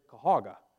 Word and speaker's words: Cuyahoga: I said, "Well, it's simple in how you pronounce Cuyahoga Falls, because everybody from Cuyahoga pronounces it Cahoga. It's Cahoga Falls."